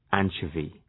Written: anchovy

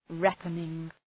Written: reckoning

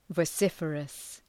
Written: vociferous

